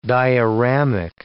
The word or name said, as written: dioramic